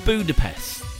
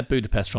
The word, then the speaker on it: Budapest: Apparently, the reason it was called Budapest was because it rhymes with treasure chest.